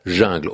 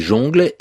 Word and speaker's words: jungle: On prononce en général jungle. Aujourd'hui, c'est la prononciation admise de façon presque euh, commune. Mais pendant bien longtemps, on a dit jongle et il y avait des puristes qui disaient qu'il valait mieux dire jongle que jungle.